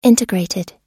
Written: integrated

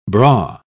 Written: bra

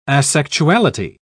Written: asexuality

